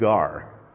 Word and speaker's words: gar: It's the Greek word "gar." It's a difficult one. G-A-R is how we would pronounce it.